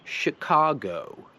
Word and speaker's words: Chicago: I w słowu Chicago się wymawia jako I. To jest she zamiast she. Nie ma she. To jest she. She, ka, go. I pamiętajcie, że o po angielsku nie jest o, jest o. Dlatego się mówi Chicago.